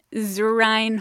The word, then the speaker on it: Zurine: Zurine Holdings. – Your slogan could be, it's spelled like urine, but with a Z.